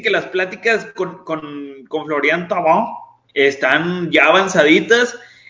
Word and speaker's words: Florian Thauvin: Florian Thauvin.